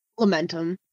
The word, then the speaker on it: Lamentum: Second up, Lamentium. Is that how you say it? I've never heard of that game. – Lamentum. – Okay, Lamentum.